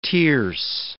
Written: tierce

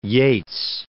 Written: Yeats

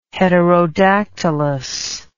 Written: heterodactylous

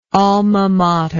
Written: alma mater

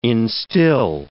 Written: instill

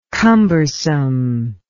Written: cumbersomeness

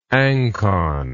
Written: anconoid

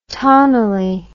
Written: tanguile

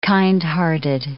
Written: kindhearted

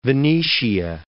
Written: Venetia

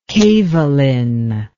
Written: kevalin

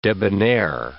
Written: debonair

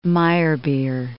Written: Meyerbeer

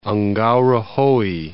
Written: Ngauruhoe

